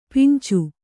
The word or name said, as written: ಪಿಂಚು